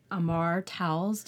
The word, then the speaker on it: Amor Towles: Uh, one book is called A Gentleman in Moscow by Amor Towles.